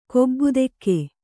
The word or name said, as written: ಕೊಬ್ಬುದೆಕ್ಕೆ